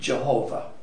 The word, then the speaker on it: Jehovah: The pronunciation of this name is not certain, and there are different theories about how it should be pronounced. The most common pronunciations are Yahweh and Jehovah.